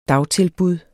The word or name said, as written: dagtilbud